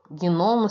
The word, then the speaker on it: gnomo: Gnomo se escreve G-N-O-M-O. É um G mudo.